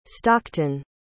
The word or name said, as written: stockton